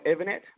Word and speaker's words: Evennett: Mate, on Instagram, it's they're both under my name, so at Scott Evanett, and it's spelled EVENNETT.